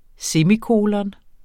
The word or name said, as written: semikolon